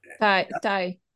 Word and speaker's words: Tai: How do you say that? You've been around the world. Tai or Tay? Tai? And Tai, Tai. – Tai, okay.